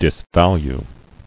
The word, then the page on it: Disvalue: dis·val·ue